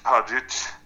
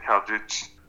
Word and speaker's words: Hadzic: Jag heter mitt namn, Erik Hadjic, eller att Erik Hadjic, med K och så stavas mitt efternamn H-A-D-Z-I-C. Så enklare att stava än att uttala.